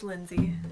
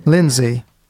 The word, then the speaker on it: Lindsay: Or is it Lin? Well, how do you actually pronounce it? It's it's just Lindsay. – Lindsay. With, it's spelled with an A, but it's yeah.